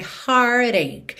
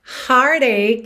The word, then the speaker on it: heartache: And that's when my heartache. Essa palavra heartache, que se escreve H-E-A-R-T-A-C-H-E, vai soar heartache.